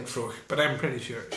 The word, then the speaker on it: Fraoch: It's spelt F-R-A-O-C-H. – So yeah. That's how you pronounce it. – I think it's Froch. – Is it Froch? – Yeah.